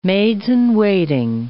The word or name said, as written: maids-in-waiting